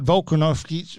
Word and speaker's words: Volkanovski: But he said Volkanovski...